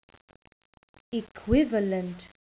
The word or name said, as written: equivalent